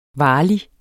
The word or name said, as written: varlig